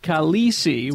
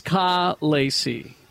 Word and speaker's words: Khaleesi: We're on the wrong syllable. Middle vowel is supposed to be completely different. Instead of Khaleesi, which is what we're saying, it's Khaleesi.